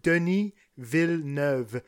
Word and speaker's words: Denis Villeneuve: So yeah, if you guys really want just a little diction class on how to say his name, it's Denis Villeneuve.